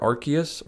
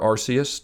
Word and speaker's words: Arceus: The other two Pokemon games that I played at this point, which are Arceus, or Arceus, depending upon who you talk to and how they pronounce that word, and Sword and Shield.